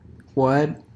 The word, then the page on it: what: American English; unstressed